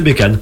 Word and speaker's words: Bécane: Bécane, c'est en Bécane, hein, c'est français, c'est un mot français, c'est Bécane.